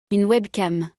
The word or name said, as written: Une webcam